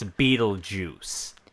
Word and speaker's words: Beetlejuice: Because it is only the opening title, I think they're just really trying to drive home so people know that it's like, guys, it's Beetlejuice. That's how you pronounce it.